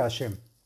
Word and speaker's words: Hashem: Y se deberá meditar el santo nombre que es Hei Shin Mem, que se pronuncia Hashem.